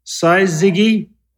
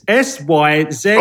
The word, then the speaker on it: syzygy: I've got to try and pronounce this correctly. Saizigi. – Don't be like Chuck now, messing up everybody. – You make the sense of this. Syzygy. – Oh, syzygy. No one actually has that syzygy.